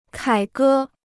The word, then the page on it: 凯歌: kǎi gē